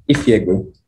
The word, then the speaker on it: Ifiegbu: Ifiegbu.